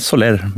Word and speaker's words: Soler: Ja, det är ett spanskt namn och jag har vant mig vid att höra alla varianter, så att jag brukar inte reagera. Men Soler.